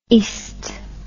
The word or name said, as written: est